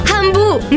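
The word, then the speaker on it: Hambu: Ambu è a Varese, Lecco, Meda e Vimercate. Ambu si scrive con l'H. – Ambu, mi piaci tu.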